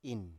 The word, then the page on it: in: Biak